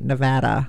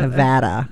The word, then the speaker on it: Nevada: or am I saying it right? Nevada. No, Nevada.